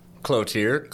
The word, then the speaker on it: Cloutier: Cloutier. Any pronunciation will do.